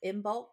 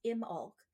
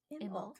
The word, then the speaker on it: Imbolc: There's also uh, for bulk. How do you say that? It's not bulk. Oh wait, pronounced Imbolc. Imbolc.